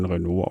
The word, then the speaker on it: Renaud: also so das Beste von Renaud aus den Jahren 75 bis 85. – Wobei nicht zu verwechseln mit der Automarke, nee, die schreibt sich anders, es spricht sich zwar gleich, aber Renaud... wie Renault, ne?